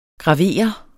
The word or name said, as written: graver